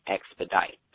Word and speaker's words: Expedite: All right, Saint Expeditus, which is spelled Saint Expeditus, but it's really Saint Expedite, and it's actually his feast day today.